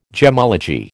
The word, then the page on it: gemmology: American English